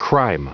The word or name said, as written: crime